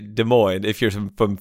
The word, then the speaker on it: Des Moines: S is silent. Both S's, right? – There's actually 14 S's in Des Moines. – Because it should be Des Moines. – Des Moines.